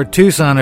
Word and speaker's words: Tucson: Or Tucson, if you're pronouncing it non-phonetically.